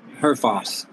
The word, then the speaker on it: Herfoss: Herfoss, yeah. – Herfoss. – H-E-R-Foss. – Yeah. Foss, okay. – Exactly how it's pronounced, how it reads, really.